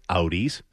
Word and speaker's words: Audis: Agenda-free Fridays are driven by the Bernardi Auto Group, where car buying is always agenda-free, where you can choose from new Acuras, Audis, see, that's how you say it, Mike, Hondas, Toyotas, Volvos, and over 500 pre-owned vehicles.